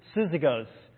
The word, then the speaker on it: Syzygos: And this proper name is, again, I got the pronunciation, I think, Syzygos. So it's S Y Z Y G O S.